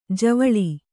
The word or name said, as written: ಜವಳಿ